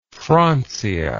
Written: francia

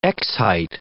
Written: x-height

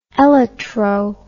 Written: elytro

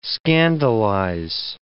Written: scandalize

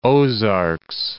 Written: Ozarks, Lake of the

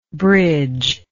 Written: bridgelike

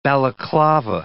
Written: balaclava

